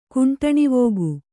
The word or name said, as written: ಕುಂಟಣಿವೋಗು